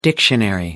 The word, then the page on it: dictionary: American English